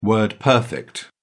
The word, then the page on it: word-perfect: British English